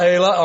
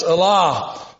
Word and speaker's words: Allah: It's not Elah or Elah, it's Allah.